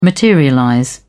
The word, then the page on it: materialize: British English